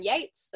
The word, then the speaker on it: Yeates: Otherwise, if you want to personally follow me, my Instagram is Lauren Yeates, and that's spelled Y E A T E S.